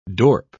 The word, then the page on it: dorp: British English